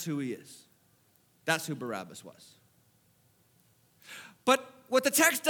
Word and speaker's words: Barabbas: His name is Barabbas, but you would say it like this, Barabbas. It's a two-part name.